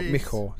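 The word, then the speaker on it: Michał: Well, I understand the L doesn't get mentioned, doesn't get pronounced on Micho. So it's more Michor, so because it's an L with a line through it.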